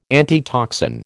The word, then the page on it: antitoxin: American English